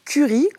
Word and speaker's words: Kuri: Alors, la première appli dont on va parler, elle s'appelle Curry, comme la fameuse épice, mais ça s'écrit K-U-R-I.